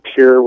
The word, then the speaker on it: Pierre: I live in Pier, which is spelt like Pierre, but we say Pier.